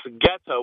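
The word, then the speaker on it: goetta: And so the people that lived in Cincinnati spoke High German, which this geta was how you pronounce the food that they made.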